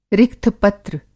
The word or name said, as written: रिक्थ-पत्र